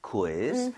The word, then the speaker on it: quiz: 읽을 때 이거 퀴즈라고 하지 마시고요. 우이를 따로 다 발음해야 돼요. – Quiz. – Quiz. 그 대신에 빠르게 하는 것뿐이에요. So quiz 아니라 quiz. Quiz. 좀 이런 식으로 퀴 말고요.